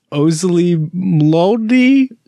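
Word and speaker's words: Oczy Mlody: It's Ozzy Mlody, O-C-Z-Y. – Oh, okay. Okay. – Space, all right, I see it. – M-L-O-D-Y.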